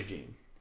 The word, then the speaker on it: Jean: His name is spelled J-E-A-N. – Mm-hmm. Jean. – It's actually Gene. He's American. – Right, but it's spelled Jean. – Right. – But it's pronounced Gene.